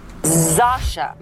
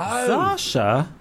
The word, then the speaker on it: Zosia: So the correct way to pronounce Zosia Mamet is... – Zasha Mamet. – Oh. Zasha? – Zasha.